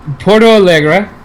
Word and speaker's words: Porto Alegre: So Halloween and November 1st, we have Porto Alegre, Brazil. I'm going to go ahead and assume that was correctly pronounced. – I'll give it to you. – It sounded right.